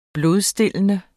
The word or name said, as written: blodstillende